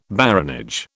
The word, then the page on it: baronage: British English